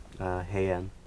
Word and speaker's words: Heian: Heian.